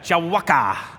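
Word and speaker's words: Jiwaka: You have to say it like that, Jawaka.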